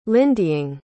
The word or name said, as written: lindying